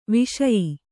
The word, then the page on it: ವೈಷಯ: Kannada